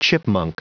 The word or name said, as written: chipmunk